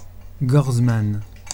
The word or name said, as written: gorzmann